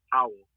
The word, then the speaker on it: Powell: You can go to my Facebook page at Free George Powell. And my last name is spelled P-O-W-E-L-L.